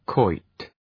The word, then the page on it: quoit: kwɔıt